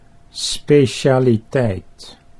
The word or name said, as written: specialiteit